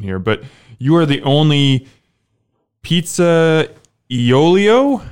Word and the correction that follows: pizzaiolo: How bad did I say that? – Well, it's Pizzaiola. – Pizzaiola. So, I totally screwed that up.